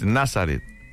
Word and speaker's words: Nazareth: ¿Así lo pronuncias? No, no dices Nazareth, ¿verdad? Es Nazareth. Nazareth.